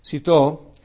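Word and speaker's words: Cîteaux: Otro monasterio en una ciudad también, ¿cómo pronunciamos esto? Citó. Lanzó una reforma llamada la Cisterciense,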